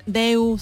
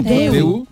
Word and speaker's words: Daewoo: Pero allí no se Daewoo, sí, Deu se dice. – Ah, Deu.